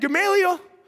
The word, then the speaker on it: Gamaliel: He had Gamaliel as his teacher. I'm saying that right, is that the right one? Come here. Okay, thank you.